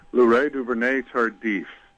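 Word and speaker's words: Laurent Duvernay-Tardif: Louray Duvernay-Tardif.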